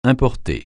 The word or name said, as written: importer